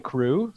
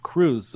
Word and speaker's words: krewe: But the spelling in this case is crew, K-R-E-W-E. And that is based on English spelling of or British spelling of the word crew. And this is because the different Mardi Gras parades that we have in New Orleans are all called crews. So you can belong to a crew. It's spelled K-R-E-W-E.